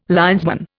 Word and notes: linesman: Indian

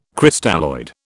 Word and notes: Crystalloid: British English

